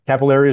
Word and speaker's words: Cappelleri: Cappelleri is right. I like to say, sounds like the vein.